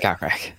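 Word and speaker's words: Kokrak: Or Corkrack. I might be saying that wrong. – No. It's Cochrack.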